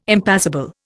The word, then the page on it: impassible: Indian